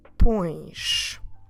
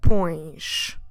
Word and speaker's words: pões: That's right, quite nasal. Oh, como se escreve pões? P O com til é S. So this verb form requires an accent, the accent that we call til. I'm talking about that little wave that always makes the vowel sounds nasal.